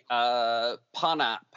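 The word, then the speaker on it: Pohnpei: It's the Ponap is how it's pronounced now.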